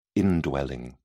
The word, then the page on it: indwelling: British English